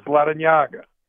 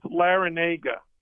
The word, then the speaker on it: Larrañaga: Okay, so the correct pronunciation, because the N in my last name has an enye, a tilde. It's an accent mark. And the correct pronunciation is Larrañaga. It's Cuban. – Okay. – But when I was in kindergarten, I went to a Catholic school, and the nun couldn't pronounce it correctly. When I try to correct her, which you don't do in Catholic schools, she said to me, no, I think it's Larinaga.